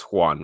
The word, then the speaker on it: Jwan: Juan? Joseph? Juan. – Yeah. – J-W-A-N. That's Juan, right? – Yeah.